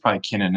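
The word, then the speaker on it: Kinnan: One of the things I like about Keenan is, Keenan is the pronunciation.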